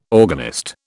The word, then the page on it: organist: British English